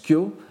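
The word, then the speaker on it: kyo (経): ça s'écrit kefu et ça se prononce kyo, et euh, au sens de sutra, ça, s'écrit kiaou et ça se prononce aussi kyo.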